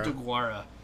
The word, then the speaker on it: Deguara: DeGuara.